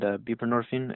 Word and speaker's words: buprenorphine: Like, what is the balance there or the difference between, say, methadone and buprenorphine, if I'm saying that right? – You're saying it perfectly.